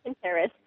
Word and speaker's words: Pinterest: It's interest with a pin in the front. – Right, yeah. – Pinterest.